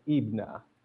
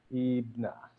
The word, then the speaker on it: IBNA: It is actually pronounced IBNA. IBNA. – So, okay. – IBNA. Yeah, it's actually, it translates to an Arabic word, and it translates into son of all of us.